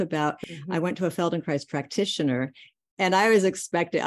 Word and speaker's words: Feldenkrais: I'm always bad at like pronouncing Feldenkrist method. – Oh, Feldenkrais. Feldenkrais.